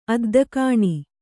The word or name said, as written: ಅದ್ದಗಾಣಿ